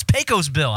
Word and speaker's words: Pecos Bill: Pesco Bill. That's Crypto Pete. – Why did I call him Pesco Pete? Pecos. Oh, no. It's Pecos Bill. I'm mispronouncing it.